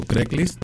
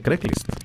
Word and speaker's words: Craigslist: Es que fíjate, en mexicano se dice Craigslist. – Craigslist. – No Craig. No, Craigslist, en sí se Craigslist. – Craig. – Como si fuera Greg, pero con C.